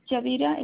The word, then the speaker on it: Yadira: Yes, Yadira, it's Y-A-D-I-R-A. – You said Y-A-D? – Otero. Uh-huh. I-R-A. – I-R-A.